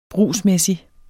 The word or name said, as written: brugsmæssig